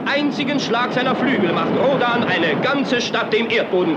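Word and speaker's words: Rhodan: Der Nachname von Perry Rodan wird nämlich deutsch ausgesprochen und nicht englisch.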